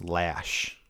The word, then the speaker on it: Laesch: Yeah, L-A-E-S-C-H is how he spells it. It's pronounced Lash.